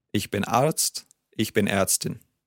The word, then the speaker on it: Ärztin: Different vowel sound there. Die Ärztin. – Yes, A, Arzt und Ärztin. The A sound.